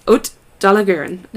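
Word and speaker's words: Haute de la Garenne: Haute d'Allegaron. – Haute, how do you say that? – Haute Dallagurin.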